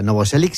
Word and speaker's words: Novoselic: Pero Novoselic, ¿no? Lo he dicho bien, ¿no? – Es como Thor. – O sea, Novoselic sí, el, ah, vale.